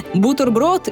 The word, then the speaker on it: бутерброд: Возможно, для кого-то будет открытием, но в словарях зафиксировано произношение «бутерброд» и «свитер», а не «бутерброд» и «свитер». Нормой считается твердый «т» в словах «террор», «декан», «дефис», объясняет создатель канала «Лингвошутки» редактор Лена Лукьянова.